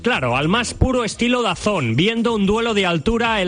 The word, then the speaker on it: DAZN: DAZN, pero se pronuncia DAZN.